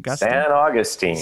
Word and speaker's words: St. Augustine: Is that how you pronounce it? St. Augustine? – San Augustine. It's close enough there, George.